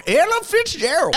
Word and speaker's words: Ella Fitzgerald: Oh, that would be Ellen Fitzgerald. – Ellen Fitzgerald? – Ellen? – No, I said it right. Ella Fitzgerald.